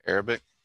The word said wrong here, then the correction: Arabica: Arabica. – Arabica? – Yes, you're right. – Is that the same thing? So am I just saying it wrong? – It's Arabica. – Are you sure? – If you take the A off, it's Arabic, but it's Arabica, I'm pretty sure is the word.